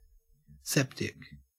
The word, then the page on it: septic: Australian English